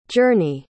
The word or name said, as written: jurnee